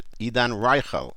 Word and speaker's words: עידן רייכל: עידן רייכל That's the way he pronounces it. עידן רייכל I'll have to... repeat that and repeat that until I get it right.